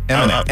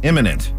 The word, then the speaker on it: imminent: Well, it's spelled I-M-M, so that's why I'm going with imminent, because that's the way it's spelled.